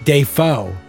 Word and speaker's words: Dafoe: By the way, he said on Conan the other day, they pulled up an old interview, his name is actually pronounced Dafoe.